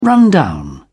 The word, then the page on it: run down: British English